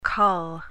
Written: col

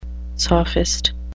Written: sophist

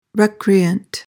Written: recreant

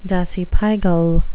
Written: dasypygal

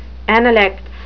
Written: analects

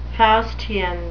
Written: faustian

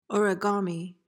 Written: origami

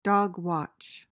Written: dogwatch

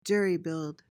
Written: jerry-build

